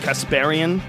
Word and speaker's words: Kasparian: Kasparian, yes.